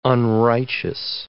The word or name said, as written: unrighteous